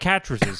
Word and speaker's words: Catrices: Mike Catrices says, Matt rhymes with mattresses.